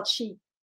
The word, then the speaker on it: Qi: Now, Qi, Q-I, is pronounced Qi.